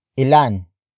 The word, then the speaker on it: ilan: Word for word, it's ilan, ang, kamatis, sa, basket.